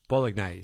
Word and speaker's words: bolognese: Chutney in your spaghetti bolognese. One of you has taken the time to write in very cheerily, said, Bolognese. Say it properly. Bold, not bog.